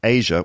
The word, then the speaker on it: Aja: And my recommendation, Asia, which is spelled AJA by Steely Dan, which is an old album, but it's a classic.